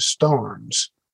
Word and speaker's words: Starnes: I'm pretty sure that the way that the old preacher himself pronounced his name, and certainly the way the descendants of his brothers have, is Starnes. They spell that S-T-A-R-N-E-S as well.